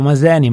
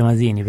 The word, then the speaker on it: Masini: La realtà, come vuoi tu, Maseni, diciamo Maseni, ma anche Masini,